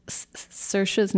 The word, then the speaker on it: Saoirse: Um, well, first I have to compliment you because you pronounce Saoirse's name right. You say Saoirse, and that's actually the Irish way to say it.